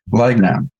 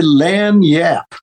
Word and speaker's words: Lagniappe: Lagnap. Am I saying that right? Uh, Lan Yap. Lan Yap. Okay. Oh, you don't pronounce the G. Nope. Uh, Lan Yap. All right. – It's a French thing, you know.